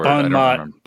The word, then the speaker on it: bon mot: Bon mot. – Bon mot. Yes, because we were calling it bon mot, and he's like, it's bon mot.